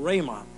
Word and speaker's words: Rhema: It's called Rhema. R-H-E-M-A is the way we would spell it.